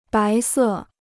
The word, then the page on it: 白色: bái sè